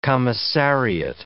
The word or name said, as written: commissariat